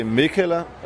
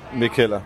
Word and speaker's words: Mikkeller: You either say Mikkeller or Mikkeller.